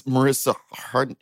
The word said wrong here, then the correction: Mariska Hargitay: Mariska Hargitay, how dare you? – Icon.